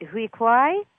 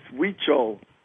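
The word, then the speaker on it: Huichol: Is it Hui Kwai Shaman? – Well, that was pretty good. It's Weechol.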